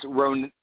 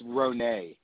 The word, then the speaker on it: Ronay: Oh, it's Ronay. It's okay. It's Ronay.